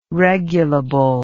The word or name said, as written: regulable